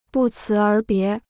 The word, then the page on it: 不辞而别: bù cí ér bié